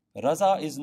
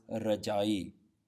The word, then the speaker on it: Raza'i: So here the word Raza'i, even though it's not spelt with a Z, because the Z, Jajipair Bindi, doesn't exist in the Gurmukhi alphabet, it shouldn't be pronounced Raja'i.